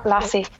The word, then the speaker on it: Lasse: Yeah, Lasse.